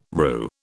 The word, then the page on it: roux: British English